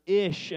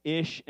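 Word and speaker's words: ish (אִישׁ): Man is this word ish, and woman is this word ish-ah. And so even in the way that Hebrew pronounces man and woman, it is this idea that woman has been taken out of man, ish and ish-ah.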